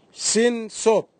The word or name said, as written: sinsop